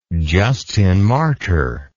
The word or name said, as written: justinmartyr